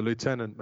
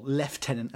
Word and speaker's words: lieutenant: Or the lieutenant, maybe. – Yeah. Or the lieutenant, yeah. Mm-hmm. Or, sorry, well, lieutenant, as we should say. – Lieutenant. – Proper way to say.